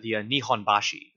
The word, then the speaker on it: Nihonbashi: Specifically, I should have asked how to pronounce this at the beginning of before we started, the Nihonbashi. – That's right. The pronunciation's correct, yes.